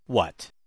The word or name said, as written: what